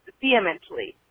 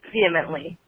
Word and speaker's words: vehemently: And you guys keep saying vehemently, or some, you keep pronouncing the H, and the H is silent. The way you pronounce vehemently is vehemently, just vehemently.